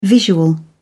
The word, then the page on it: visual: British English